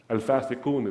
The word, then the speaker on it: Al-Fasikun: Al-Fasikun is how it's pronounced in Arabic.